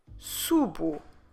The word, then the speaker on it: subo: So the first person is eu. Subo, eu subo. And here we have clearly the sound U, just like we have it on the infinitive. Subir, eu subo. Como se escreve subo? S-U-B-O.